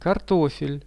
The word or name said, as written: kartofel